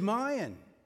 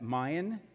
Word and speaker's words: mine: Mine is not a two-syllable word. Is mine. My grammar teacher always corrected me on that. Mayan is not a two-syllable word. It's a civilization down in Central America. Mayan.